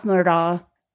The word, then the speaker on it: Murdaugh: So Rudolph Murdaugh, it's like it rhymes. It kind of does not roll off the tongue the way you think it would.